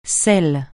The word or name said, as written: sel